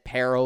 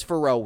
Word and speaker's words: Pharrell: Derek didn't know how to pronounce Pharrell Williams' first name, so he said Peril or Pearl. But it's Pharrell Williams.